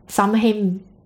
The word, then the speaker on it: Samhain: It used to be called Samhain, and it's spelled S-A-M-H-A-I-N. – Samhain. – Yeah, but it's pronounced Samhain.